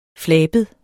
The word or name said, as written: flabet